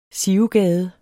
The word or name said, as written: sivegade